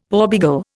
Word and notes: porbeagle: Indian

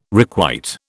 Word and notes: requite: British English

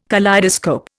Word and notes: kaleidoscope: Indian

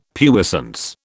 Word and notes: puissance: British English

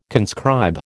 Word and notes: conscribe: American English

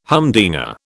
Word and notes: humdinger: British English

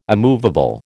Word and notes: immovable: American English

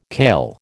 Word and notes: kail: American English

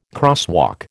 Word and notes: crosswalk: American English

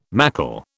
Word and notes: mackle: British English

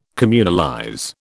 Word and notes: communalize: British English

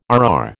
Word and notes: RR: American English

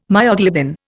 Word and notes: myoglobin: Indian

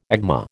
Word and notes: agma: American English

